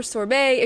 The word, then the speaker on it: sorbet: As for sorbet, if you want to remember how to pronounce it, just do what I do and act like you're singing Raspberry Beret by Prince and sing Raspberry Sorbet instead.